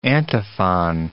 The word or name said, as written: antiphon